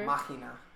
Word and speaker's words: Machina: Demon X, I have always say this wrong because I never know if it's Machina or Machina or whatever. – Machina.